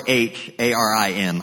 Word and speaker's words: Harim: If you're taking notes, that's spelled, it's a hard H, A-R-I-M, Harim Mandate.